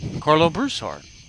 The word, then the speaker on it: Karlo Broussard: No, it's Broussard, not Broussard. – Broussard. – The emphasis is on the ooh.